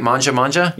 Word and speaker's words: Mangia Mangia: They are the owners of Mangia Mangia Italian Kitchen. Did I say it right? Mangia Mangia? – You did.